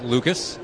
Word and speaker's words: Lukes: Down and in to Lucas, who actually spells his last name L-U-K-E-S, but it's pronounced Lucas.